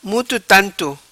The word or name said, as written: Muto Tanto